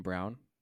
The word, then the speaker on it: Braun: There's another Braun who likes to pronounce his name Brown.